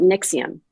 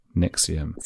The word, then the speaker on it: NXIVM: It's called Nixium. – Nixium. – So it is NXIVM. – That V at the end, then, they're using it like the old-fashioned V from like medieval times.